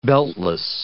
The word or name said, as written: beltless